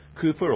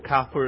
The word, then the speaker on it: Cowper: Well, listen to the words of William Cowper, or Cowper, if you choose to pronounce it that way.